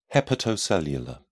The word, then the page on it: hepatocellular: British English